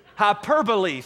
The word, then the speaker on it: hyperbole: And what Jesus is doing is he is using a hyperbole. What is the word? I've said it wrong all too. Hyperbole.